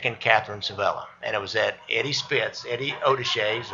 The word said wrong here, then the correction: Osatchi: or how did you pronounce Osatchi. – That? Osatchi's. – Yeah. – Probably more correct pronunciation. Well, I've got the hillbilly pronunciation.